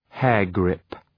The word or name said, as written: hairgrip